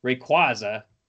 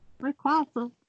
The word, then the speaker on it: Rayquaza: All right, I got no right to try and pronounce Pokemon that I think is correct, because it's been established I was wrong about Rayquaza, because it's Rayquaza. – Rayquaza.